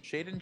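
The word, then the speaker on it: Shaedon: You know, we, we talked last time about the Jerami Grant trade, uh, the, the drafting of uh, Shadon Sharp, Shaden Sharp, Shaden or not not Shadon, Shade, despite the spelling.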